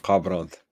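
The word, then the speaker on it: Kharbranth: You call it Karbranth or Harbranth? – Karbranth. – Okay.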